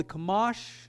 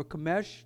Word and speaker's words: Chumash: The first five books is called the Chumash, or Chumash, depends how you pronounce it.